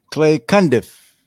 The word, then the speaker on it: Clay Cundiff: Clay Cundiff.